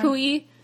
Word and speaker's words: Kui: So, this week, the graphic novel that I chose is a manga called Delicious in Dungeon by Ryoko Kui? Kui? – Let me see. – I'm blanking. I know Chinese, and I've learned Ryoko some Japanese. Kui. Yeah. Kui is correct, according to...